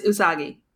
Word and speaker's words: Usagi: I was going to pronounce, I always say, it's not Usagi, not Usagi, correct? – It's Usagi.